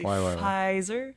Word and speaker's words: Pfizer: Pfizer, ça s'écrit avec un P, mais tu le prononces pas vraiment, là. – C'est Pfizer.